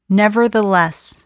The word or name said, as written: nevertheless